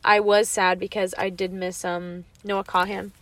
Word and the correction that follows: Kahan: Or it's actually pronounced Khan. Noah Khan.